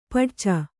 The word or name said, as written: ಪಡಚ